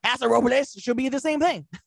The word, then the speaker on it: Robles: Yeah, it is, because like correctly, it's Robles. – Robles. – But Paso of course, Robles? All the white locals, it's Robles.